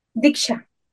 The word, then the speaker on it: Diksha: Es Master Reiki, es Dishka, ¿se pronuncia así? Diksha. Disha.